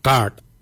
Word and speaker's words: Gaad: Yimung is spelled Y-I-M-O-O-N-G. Yimung. Yimung is forehead. The next word is head. For head, we say kart. Kart. Kart is spelled G-A-A-D.